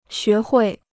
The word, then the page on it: 学会: xué huì